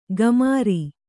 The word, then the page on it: ಕಮರಿ: kamari; kannada